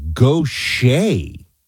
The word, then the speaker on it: Gautier: How to pronounce Gautier, it's Gautier. I'm from Mississippi, Paul says. I would not have gotten this.